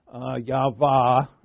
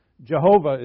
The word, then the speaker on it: Yahweh: The four-letter YHWH Yahweh, really, what the right pronunciation I think is Yahweh. Jehovah is, we know, completely wrong.